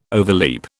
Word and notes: overleap: British English

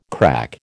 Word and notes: crack: American English

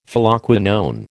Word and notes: Phylloquinone: American English

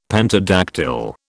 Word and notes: pentadactyl: British English

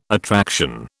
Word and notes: attraction: British English

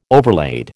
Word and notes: overlaid: American English

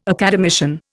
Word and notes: academician: Indian

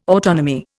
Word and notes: autonomy: Indian

